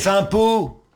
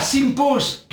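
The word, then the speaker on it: Sympos: Donc voilà, il faudra aller regarder sur la playlist Synops. On est dimanche, il se fait tard, tout le monde est fatigué. – Simpos. – Ah, Simpos.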